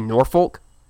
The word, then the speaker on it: Norfolk: Yeah, you pronounce it like people from outside the area, and like the people from Norfolk will get mad when you say Norfolk. – Norfolk. – Yeah, you'd be like, that. You don't pronounce the L.